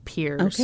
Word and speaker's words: Peairs: No, it's spelled P-E-A-I-R-S, which I think is very complicated. – That's not Paris? – Multiple articles told me that it was pronounced Pierce.